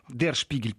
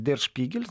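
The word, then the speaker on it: Der Spiegel: И Шпигель, де, Дер Шпигель, правильно говорить, не просто Шпигель, Дер Шпигель,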